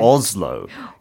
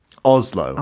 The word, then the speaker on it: Oslo: 근데 오슬로가 아니라 오슬로? – Oslo. Oh, pardon me. Oslo is how Brits pronounce it, yes.